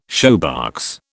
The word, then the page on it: shoebox: British English